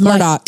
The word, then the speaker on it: Murdaugh: Is it Murdaugh or is it Murdaugh? – It's Murdaugh. – The locals say Murdaugh.